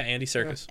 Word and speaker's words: Andy Serkis: Yeah, Andy Serkis.